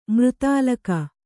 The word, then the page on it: ಮೃತಾಲಕ: kannada